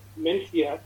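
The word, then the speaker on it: Mencía: It's a very unusual Spanish variety called Mencia or Mencia, as it's pronounced in Spanish.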